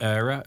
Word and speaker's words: Eyra: They spell that E-Y-R-A. – Yes. – Okay, yes. – So it might sound like Era, as in E-I-R-E, but it's E-Y-R-A if you want to search that.